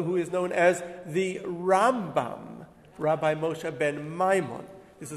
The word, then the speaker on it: Rambam: Um, but they did, uh, but their names are similar in order to differentiate, the way Jews have traditionally done it is we call the Rambam, Rabbi Moshe ben Maimon, Maimonides, we put the stress on the beginning of the word.